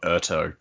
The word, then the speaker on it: Urteau: I thought Urto because it's Urto. The same spelling as the end of plateau.